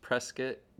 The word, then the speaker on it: Prescott: It looks like Prescott. It's pronounced Prescott. The locals will haggle you about it.